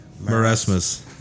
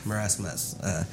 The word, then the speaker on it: marasmus: Her only daughter, Annie, died from the how do you say that? M-A-R-A-S? – Marasmus. – Marasmus.